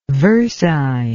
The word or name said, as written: versine